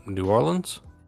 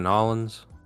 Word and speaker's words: New Orleans: Or the New Orleans? Oh, you just pronounce. I thought it was. – New Orleans. – New You can say New Orleans. You can say Nollins.